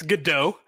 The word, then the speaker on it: Godot: Did I say it wrong? Is it Godot or is it Godot? – It's Godot.